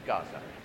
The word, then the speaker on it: Gaza: And because he said Gaza, I thought, that's how we pronounce it. Okay, Gaza.